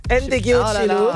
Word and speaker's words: Ndegeocello: Autrice, compositrice, chanteuse américaine, Michelle Ndegeocello. – Ndegeocello.